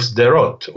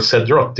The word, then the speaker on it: Sderot: And just to illustrate to the listeners what that means, many listeners will have heard of the Israeli town of Sderot or Sedrot, depending on how you pronounce it.